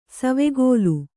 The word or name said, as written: ಸವಿಗೋಲ